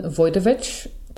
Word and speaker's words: Wojtowecz: Today I am talking to Allison Wojtowecz.